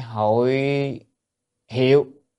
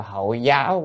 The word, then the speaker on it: hội hiệu: Thứ hai, hội hiệu, hay có khi đọc là hội giáo cũng được, nhưng mà nên nên đọc học hiệu thì người ta quen rồi.